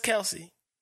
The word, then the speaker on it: Kelce: Except for his mom came out and said, nah, it's Kelce.